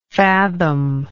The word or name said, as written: fathomer